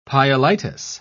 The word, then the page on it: pyelitis: British English